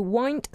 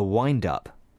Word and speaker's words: wind: But it can also be used as a noun, a wind-up. – 这个短语还可以变成一个名词, a wind-up. – Be careful with the pronunciation because it's spelt the same as wind, but pronounced wind.